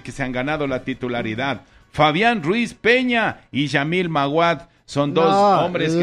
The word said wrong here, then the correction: Lamine Yamal: Lamine Yamal. Lamine se escribe. Lea, ahí está, Lamine. Lamine Yamal. – No es Yamil Maguad. – Yes. No, Lamine Yamal.